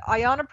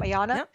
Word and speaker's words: Ayanna: Melanie Zanona from The Hill said, Ayanna Pressley, is that how you say her name? Ayanna? – Yep.